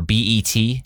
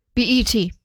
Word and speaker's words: BET: Am I saying it right? – BET. – It's not Bet? – I don't think so.